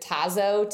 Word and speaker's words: Tazo: Is it Tazo tea? – Yep.